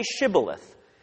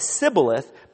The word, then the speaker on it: Shibboleth: If he said no, they'd say, "Okay, say Shibboleth." If he could not and said Sibboleth, because he could not pronounce the word correctly, they seized him and killed him at the fords of the Jordan.